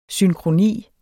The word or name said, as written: synkroni